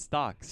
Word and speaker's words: Stocks: The Stokes, bro. The name for your thing. Where did that come from? – It's pronounced Stocks. – Stocks, sorry.